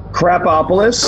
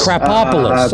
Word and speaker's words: Krapopolis: Krapopolis. – Krapopolis. I thought it was a T in it.